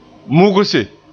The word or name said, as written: mugse